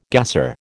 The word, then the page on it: gasser: American English